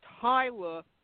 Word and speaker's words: Huyler: Last name is pronounced Hyler, spelled H-U-Y-L-E-R, but pronounced as if the U wasn't in it. It's an Ellis Island thing.